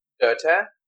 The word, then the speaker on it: Goethe: G-O-E-T-H-E? – Goethe? – Goethe?